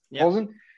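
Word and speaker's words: Plzeň: Plzen, however you pronounce it, Plzen.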